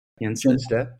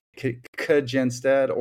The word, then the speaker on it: Kjenstad: Oh, they usually overemphasize the K. Kjenstad,